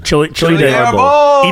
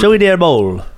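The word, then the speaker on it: chile de árbol: How do you say that? – Arbol edition. – Chili de Arbol. Even with the accent? Even with the accent? – Chili de Arbol.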